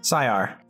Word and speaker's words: Siar: How do you pronounce it properly? – Sayar. – For the second week in a row. – Sayar. Sayar. – I gotta put that in phonetically. – S-I-A-R.